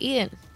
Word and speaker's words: Eaton: Well, that's how you say it, Eaton.